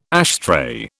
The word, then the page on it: ashtray: British English